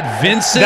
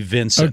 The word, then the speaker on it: Vyncint: Is that Vincent? – That was my guess. – It has to be Vincent. – Okay, why don't you tell him how it's spelled? – V-Y-N-C-I-N-T.